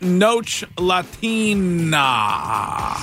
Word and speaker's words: Noche Latina: That's pronounced Noche Latina. – Sorry. No say Latina? – No say Latine.